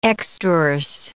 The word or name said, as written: extrorse